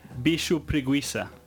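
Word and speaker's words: bicho-preguiça: The Slav, do you know how to say this properly? – Bicho preguiça. – There we go.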